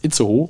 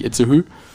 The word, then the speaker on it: Itzehoe: Da ich da beim Bund war, kann ich dir sagen, es heißt Itzehoe und nicht Itzehoe. – Itzehoe, ne? – Aber nee, nicht Itzehoe, das ist wieder Englisch.